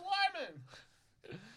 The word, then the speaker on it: Luhrmann: It's Baz Luhrmann!